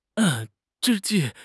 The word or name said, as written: 之际